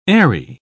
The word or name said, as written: aerie